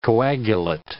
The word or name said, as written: coagulate